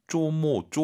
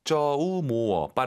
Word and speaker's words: 周末: 쪼모, 쪼모 아니에요. 쩌우, 오유는 어우니까. 그리고 모어, 쩌우, 모어. 빠르게 발음해서 주말.